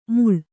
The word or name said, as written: ಮುಳ್